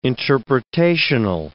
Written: interpretational

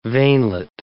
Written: veinlet

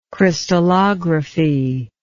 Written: crystallography

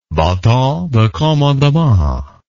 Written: baton de commandement

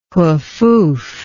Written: Hufuf

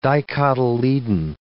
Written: dicotyledon

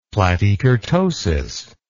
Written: platykurtosis

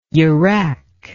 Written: yurak

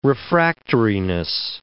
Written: refractoriness

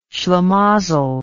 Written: shlimazel

